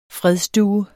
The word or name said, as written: fredsdue